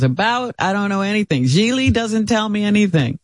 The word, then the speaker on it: Gigli: Geely is how you say it. It's spelled G. It's spelled G-I-G-L-I, but it's Geely.